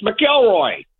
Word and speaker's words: McIlroy: There's no N in his name. – Wait, Nantz mispronounced. I totally missed that. Did you guys catch that? – Did not. – He called him Rory McEnroy? – Yeah, I called him McEnroy, and I said, it's McIlroy.